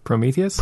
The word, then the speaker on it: Prometheus: Okay, it's a lot like Protheus, or that's what, I'm not saying it right. What is the name of that movie? – Prometheus. – Thank you, yes.